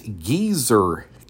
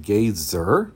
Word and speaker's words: Gezer: And we're at the famous Geezer calendar, not like an old geezer, but G-E-Z-E-R. Some might would pronounce it Geyser, but Geezer calendar.